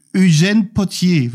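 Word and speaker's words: Eugène Pottier: Der Text im Original auf Französisch von, da musst du mir dann wieder helfen bei der Aussprache. – Eugène Potier, würde ich sagen.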